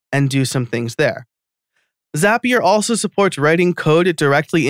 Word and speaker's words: Zapier: Zapier makes you happier, is what people say to remember the pronunciation.